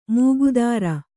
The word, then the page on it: ಮೂಗುದಾರ: mūgudār